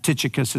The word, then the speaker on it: Tychicus: Tychicus, there's all different ways people suggest uh, for pronunciation. I think for us who are just reading strictly from English, that Tychicus is probably the, the easiest way to pronounce here.